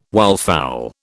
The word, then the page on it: wildfowl: British English